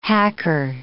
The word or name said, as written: hacker